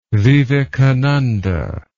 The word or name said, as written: vivekananda